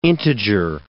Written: integer

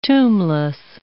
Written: tombless

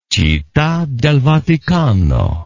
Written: citta del vaticano

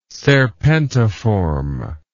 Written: serpentiform

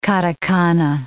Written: katakana

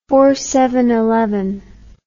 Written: fourses

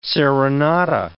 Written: serenata

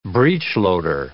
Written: breechloader

